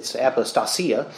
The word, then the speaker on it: apostasia: The word translated here, falling away, is apostasia. It's actually, I put the emphasis on the wrong syllable there. It's apostasia, from which we get the words in English, apostasy and apostate.